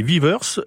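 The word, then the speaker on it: Vivès: Il faut savoir que Vives, V-I-V-E accent grave S, en catalan prononcé Vivers, V-I-V-E-R-S, est une commune française située dans le département des Pyrénées-Orientales, en région Occitanie.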